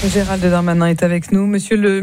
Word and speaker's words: Darmanin: Mon nom, c'est Gérald Darmanin. – Darmanin. Oui. – Darmanin, D-A-B-A-D-I-N.